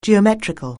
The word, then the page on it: geometric(al): anglais